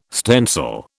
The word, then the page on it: stencil: British English